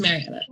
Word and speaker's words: Marianna: Yes, Marianna.